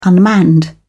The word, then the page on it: unmanned: British English